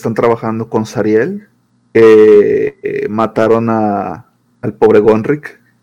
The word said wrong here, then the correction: Gonrak: Gonrak. – Gonrak, perdón. No, es que se me olvides, no lo puedo pronunciar.